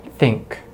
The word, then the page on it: think: American English